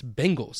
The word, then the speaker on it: Bengals: It's with an E. It's Bengals. – That's what I was saying. – You're saying Bengals.